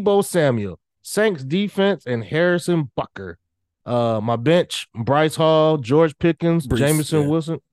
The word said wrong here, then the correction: Breece Hall: I did say that wrong. Breece Hall, George Pickens, fine.